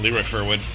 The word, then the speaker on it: Leroy Firwood: Just my same as my name, Leroy Firwood. – Yeah. You're going to have to spell that. – So, L-E-R, OYFIRWOOD.